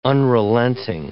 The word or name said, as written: unrelenting